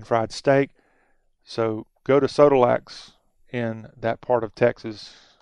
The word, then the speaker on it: Sodolak's: Sodolak's Original Country Inn. I think is how it's pronounced. S O D O L A K apostrophe S.